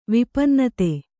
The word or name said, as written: ವಿಪನ್ನತೆ